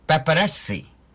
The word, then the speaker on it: পাপারাজি: এই শব্দের আসলে ঠিক উচ্চারণটা হবে পাপারাজি। – আচ্ছা, পাপারাজি না, পাপারাজি বলতে পারো বা পাপারাজিও বলতে পারো।